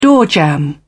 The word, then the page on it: doorjamb: British English